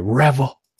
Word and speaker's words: Revvl: T-Mobile has released a phone called the Revel? Is that what we're calling it? It's got to be the Revel, right? I mean, there's no other way to pronounce that. – Spelled R-E-V-V-L.